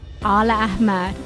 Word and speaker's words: Aleahmad: Aleahmad.